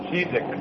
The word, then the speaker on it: Žižek: Under the name of the philosopher, which is, you pronounce it in Slovenian, so it's pronounced as Žižek. Žižek is written with Z, Z-I-Z-E-K.